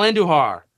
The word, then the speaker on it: Andujar: Miguel, roll your R's when you say Andujar. Miguel Andujar.